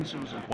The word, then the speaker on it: openSUSE: And you said OpenSUSE, that's the official pronunciation? – Yeah, OpenSUSE. – Once yeah. – and for all? – Once and for all.